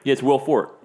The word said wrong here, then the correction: Forte: Yeah. Will Forte? – Forte. I said that wrong.